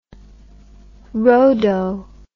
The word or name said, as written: rhodo